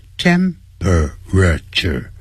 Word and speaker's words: temperature: Every year the temperature，你看就几个音节，本来是四个音节 temperature，但实际念的时候 per 那个 per 要念成 p 的音就好了，变成三个音节 temperature 每一年呢这个温度啊 is rising 都正在上升中，用了现在进行时。